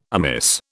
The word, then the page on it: amiss: British English